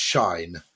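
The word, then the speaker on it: Shein: Uh, it's uh, called uh, Cheyenne, isn't it? I think, often pronounced Shine, which is a huge um, kind of Chinese clothing retailer which sells incredibly cheap clothes in the states.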